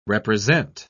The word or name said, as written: represent